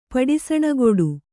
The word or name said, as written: ಪಡಿಸಣಗೊಡು